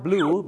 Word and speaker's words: blew: ถ้า blue b l e w ออกเสียงเหมือนสีฟ้าหรือน้ำเงินนะครับ